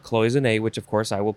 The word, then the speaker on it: cloisonné: Next is cloisonné. Cloisonné, you could also say cloisonné. Cloisonné. C-L-O-I-S-O-N-N-E.